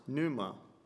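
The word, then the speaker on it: pneuma (πνεῦμα): There's a Greek word that's pronounced pneuma, meaning spirit, wind, and breath.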